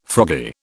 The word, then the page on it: froggy: British English